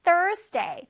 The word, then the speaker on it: Thursday: Thursday. 자, TH 발음이 있기 때문에 혓바닥으로 실뜨기 하듯이 뚝 해줘야 돼요.